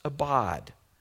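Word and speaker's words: abad (עבד): It's pronounced abad.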